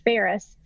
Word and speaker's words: Faris: And Faris, I know there's a lot of different variations of it, but it's spelled just like the city of Paris, but with an F, like Frank.